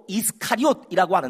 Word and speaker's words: 이스카리옷: 가룟이라는 것은 정확한 헬라어 발음으로 이스카리옷이라고 하는데 이스카리옷이라는 것은 단검이라는 뜻이다.